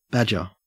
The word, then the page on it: badger: Australian English